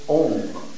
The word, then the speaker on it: Om: The letter Ah in the syllable Om. Ah, U, M.